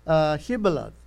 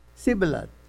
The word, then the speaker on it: Shibboleth: Okay, then say Shibboleth. So they will not pronounce properly. They, instead of saying Shah, they will say Sir, Shibboleth.